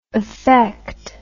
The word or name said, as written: affecter